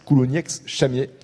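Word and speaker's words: Coulounieix-Chamiers: On prononce bien le X à la fin de Coulougnex, en l'occurrence. Coulougnex-Chamier.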